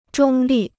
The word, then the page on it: 中立: zhōng lì